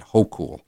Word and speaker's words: Hochul: And I never know how to pronounce it. I just looked it up online, and that's how they told me to pronounce it, Hochul.